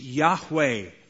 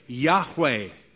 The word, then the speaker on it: Yahweh: In Hebrew, the probable pronunciation, Yahweh, Yahweh.